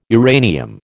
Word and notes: uranium: American English

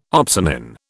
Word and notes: opsonin: British English

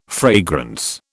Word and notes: fragrance: British English

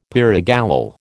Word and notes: pyrogallol: American English

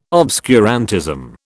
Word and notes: obscurantism: British English

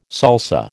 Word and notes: salsa: American English

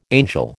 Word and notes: angel: American English

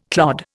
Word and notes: clod: Indian

